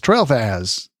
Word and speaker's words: Tralfaz: And I didn't know how to say it, but it's Trailfaz. Trailfaz is what it's called.